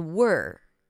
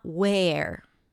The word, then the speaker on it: were: The same quality of sound also appears in the word were. W-E-R-E. Were. So here, it's the same sound. Can you hear it? Were, work. But here it's spelled with an E. So it's not where.